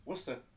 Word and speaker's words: Worcester: How do you say Worcester? – Worcester. – Yeah. Absolutely. All those extra letters are just for nothing.